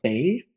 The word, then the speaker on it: Bey: So instead of having a Sultan, we have a Bey, which is not spelled B-A-E. It's more spelled like the beginning of Beyonce.